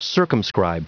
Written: circumscribe

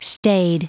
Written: staid

